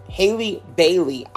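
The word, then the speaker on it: Halle Bailey: It is Haley Berry. Or no, wait. Haley Bailey.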